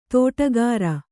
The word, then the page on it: ದಿಟ್ಟಗಾರ: diṭṭagāra